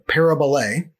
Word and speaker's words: parabole: The Greek word is parabole. And we would spell it using English letters, P-A-R-A-B-O-L-E.